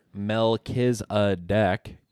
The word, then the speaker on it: Melchizedek: There was this odd little bit in the story, where involving bread and wine and this odd character Melchizedek. Yeah, I spelled that phonetically on the board. You can spell it M-E-L-K-I-Z-A, and then the word deck.